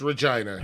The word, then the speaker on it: Regina: That's how you say it. It's Regina.